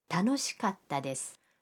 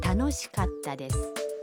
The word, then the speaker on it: 楽しかったです: 楽しいです는 楽しかったです. – 즐거웠어요가 됩니다. – 갔다는 카와 타 사이에 한 박자를 넣어야 합니다. – 그럼 여러분도 따라서 발음해 보세요. – 楽しかったです.